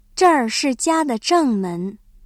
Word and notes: 正门: zhèngmén

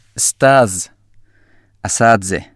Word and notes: إستاذ (أساتذة): シリアのアラビア語

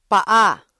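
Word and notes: paa: Filipino

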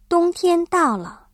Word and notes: 冬天: dōngtiān

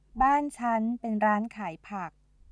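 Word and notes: ร้านขายผัก: Thai

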